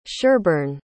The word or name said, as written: sherborne